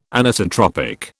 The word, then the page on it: Anisotropic: British English